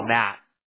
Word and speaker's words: Matt: My name is Matt, by the way, Adrian. – Adam? – No, Matt.